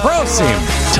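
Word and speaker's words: prosím: Proceem. – Top. – Proceem to you, too. – To the man, cause it's always right. I forgot you have to emphasize the first syllable.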